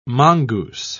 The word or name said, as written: mongoose